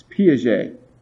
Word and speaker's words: Piaget: Well, another significant person in child development who worked primarily in the cognitive domain was a man by the name of Piaget. That's how you pronounce his name. It's Piaget.